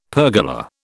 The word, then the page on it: pergola: British English